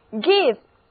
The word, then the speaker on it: give: give， G-I-V-E，字母 I 的发音是短 e，它的意思是给、赠送、让步。